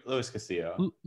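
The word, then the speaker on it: Luis Castillo: But let us not forget, they have Castillo, who is you pronounce it Louis or Lewis? – Louis Castillo.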